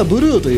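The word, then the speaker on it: #4CA7B8: まず、ブルーなんですがね、こちらね読み方がブルーなんですけど、表記がね特殊なんですよ。シャープ 4CA7B8 というね、これは何だと一瞬思いますよね。まあ一瞬というか、どれだけ考えてもちょっとよくわからないなって思う方も多いと思うんですけど、まあ、読み方がブルーというだけあってね、これはねカラーコードですね。コードです、コード。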